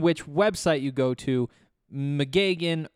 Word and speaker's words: McGeoghan: For now, we're going McGagan. For those of you who are curious, it's spelled M C G E O G H A N. We're going McGagan, and we're going to be saying McGagan a lot today because McGagan is a big piece of the Buffs news.